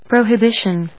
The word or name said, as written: prohibition